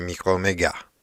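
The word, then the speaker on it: Micromégas: Nel 1752 pubblicò, scrisse un altro racconto filosofico molto molto interessante, che noi in italiano chiamiamo così micromega, anche se eh, probabilmente in francese si dice micromega e si scrive anche con una S finale non ci soffermiamo su questo perché se poi vi piace l'argomento possiamo fare una diretta live su questo interessantissimo e originalissimo romanzetto.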